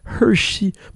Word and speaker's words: Hrshie: C'est H-R-S-H-I-E, Hershey.